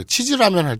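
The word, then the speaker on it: 치즈 (地図): 치즈하고 되게 치즈라면 할 때 치즈하고 되게 비슷하죠. 예, 일본어로 치즈는 치즈 네. 장음이죠. 네. 예. 다시 발음 지즈가 아니라 치즈. 치즈.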